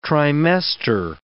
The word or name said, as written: trimester